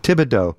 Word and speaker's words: Thibodeau: And I was told how to pronounce that guy's name, Thibodeau. I think she said Thibodeau. You don't pronounce the H.